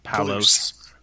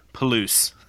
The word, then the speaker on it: Palouse: Palouse, I said it right? – Palouse. – Palouse.